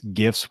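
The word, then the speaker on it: GIF: And yes, that's GIFs with a hard G because it stands for graphic interchange format.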